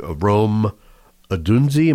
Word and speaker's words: Rome Odunze: So Arizona will be picking, and they will be taking Rome Odunze. Am I saying that right? – I believe so.